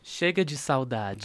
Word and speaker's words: Chega de Saudade: Chega do Saudade. Pronounce it correctly for me. Get over there. – Chega de Saudade. – Thank you so much.